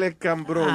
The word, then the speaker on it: Escambrón: Ah, en la playa del escabrón. – No, no es cabrón. Oh, ok, you see, el hecho que tú pongas acento en inglés no está corrigiendo la vaina. Escambrón. – No escabrón. Ok.